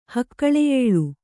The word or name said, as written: ಹಕ್ಕಳೆಯೇಳು